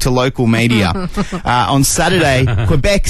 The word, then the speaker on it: Quebec: Quebec.